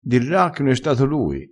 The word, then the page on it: dirra: dialettale